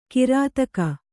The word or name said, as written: ಕಿರುದೇಗು